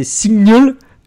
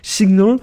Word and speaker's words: SGNL: En tout cas, ce que ne sera probablement pas cette Apple Watch 2, c'est Signal. Je suis désolé pour la prononciation, ça s'écrit S-G-N-L, Signal.